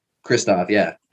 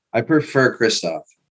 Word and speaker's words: Christophe: Oh shit, I fucked up your name. – CJ. – Do that shit again. – CJ. – Run that back. Say it right. – It's Christophe, right? – Christophe, yeah.